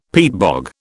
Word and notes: peatbog: British English

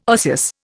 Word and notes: osseous: Indian

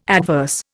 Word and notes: adverse: Indian

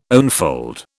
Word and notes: onefold: British English

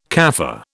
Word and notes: Kaffir: British English

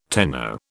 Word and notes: tenno: British English

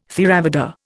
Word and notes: Theravada: Indian

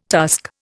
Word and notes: tusk: Indian

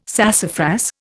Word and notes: sassafras: Indian

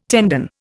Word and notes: tendon: Indian

